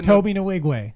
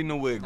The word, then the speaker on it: Nwigwe: No, you said it right. – How you say it? Toby Nwigwe. – Toby Nwigwe.